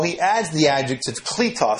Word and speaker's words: kletos: Now, the two Greek words that I'm talking about are on the board right now, and it's kletos apostolos. Kletos Apostolos. You have the K-L-E, that's an E, it's a long E, T-O-S, and then the second word, A-P-O-S-T-O-L-O-S.